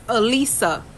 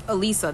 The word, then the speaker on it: Aliza: It's spelled A-L-I-Z-A, but my name is Alisa. That's how it's pronounced. Don't emphasize so hard on the Z. So pretend the Z is an S in the name, and that's how you pronounce my name.